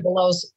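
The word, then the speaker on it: Webelos: Weebelows, yeah.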